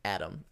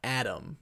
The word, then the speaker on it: Atom: And his name is Atom. A-T-O-M. Not Adam. Like Atom. – Like the Atom that you would think. It's Atom.